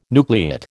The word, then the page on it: nucleate: American English